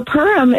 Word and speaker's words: Purim: So Purim is how it's said in America by, you know, the American Jewish population.